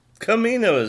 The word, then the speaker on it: Kamino: Kamino is how you pronounce it.